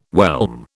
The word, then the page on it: whelm: British English